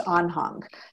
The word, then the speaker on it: Anhang: It's actually, it's a European name. And so I'm pronouncing it the North American way, but I think in German, which is where it comes from, it's Anhang.